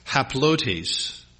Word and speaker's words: haplotes: And this word simplicity that Paul uses here, it's translated from the Greek word haplotes. Haplotes is spelled H-A-P-L-O-T-E-S.